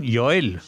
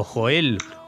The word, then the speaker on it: Joel: Y hoy, no podía ser menos, vamos a seguir con uno de ellos, con Joel, o Joel, o Joel, según se pronuncie en cada sitio, ¿no? – Efectivamente, Jorge, ya veremos las distintas pronunciaciones.